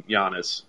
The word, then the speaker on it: Giannis: I was a big proponent of do not wait on Giannis.